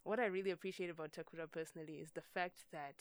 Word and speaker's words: Takura: Takura is like it's spelled T as it said, T-A-K-U-R-A.